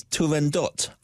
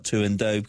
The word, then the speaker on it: Turandot: We conclude with Giacomo Puccini's Turandot. I pronounce it that way, not Turandot, because I speak Italian and lived in Rome, which he left unfinished at the time of his death in 1924.